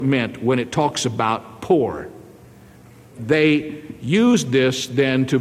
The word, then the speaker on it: pur: It's very interesting to discover that the word poor, literally, it's pronounced as if it were spelled P-O-O-R, even though it's spelled P-U-R.